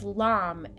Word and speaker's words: Lam: and it's Lam, L A M. Lam.